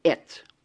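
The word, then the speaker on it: et (את): We pronounce it et, the aleph and the tav, or the tau.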